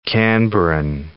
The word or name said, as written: Canberran